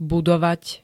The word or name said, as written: budovať